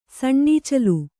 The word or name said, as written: ಸಣ್ಣಈಚಲು